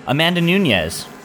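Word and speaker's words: Amanda Nunes: And how do you say her name? – Amanda Nunez. – Oh. – Pretty easy.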